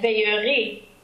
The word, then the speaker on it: veillerai: Now, in the other area we were doing earlier, in avant de quitter, we had veillerai, and that's also AI, but because it's the future tense, we pronounce it as a closed E.